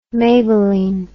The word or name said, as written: maybelline